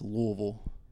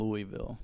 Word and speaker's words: Louisville: That's Louisville, right? – Yes. So you proper pronunciation. – You don't want to be saying Louisville. – No, don't.